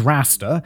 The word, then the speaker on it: Rasta: His name was Rasta, like Rastafarian.